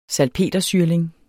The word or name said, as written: salpetersyrling